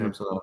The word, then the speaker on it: Samsonov: Is that how we say his name? Mm-hmm. Samsonov.